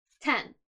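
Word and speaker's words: ten (天): So The first kanji in this word is this one right here, and it means heaven, and it is pronounced in this case, ten.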